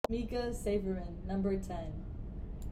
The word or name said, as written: Mika Sayfurahman